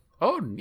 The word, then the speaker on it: Odin: Odin, yeah.